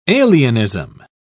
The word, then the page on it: alienism: British English